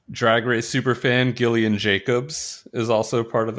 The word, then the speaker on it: Gillian: Gillian with a hard G.